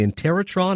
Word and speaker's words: Interrotron: And again, people often talk again about your style and the fact you invented that machine called the Interrotron. I don't know if I'm pronouncing that correctly, but you did it perfectly.